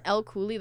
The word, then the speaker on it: Elle Cooley: If you type in Elle Cooley, that's what comes up under tenth house.